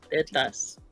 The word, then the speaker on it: tetas: Titas, how do you say it? Titas? – Tetas. – Tetas.